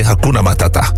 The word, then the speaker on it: Hakuna Matata: Niet die tata, nee, Hakuna Matata.